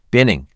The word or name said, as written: Binning